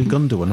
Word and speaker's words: Gundogan: So you're not pronouncing him right either. You see, it's a soft G. You see, you can't do Laporte and you can't do Gundogan either.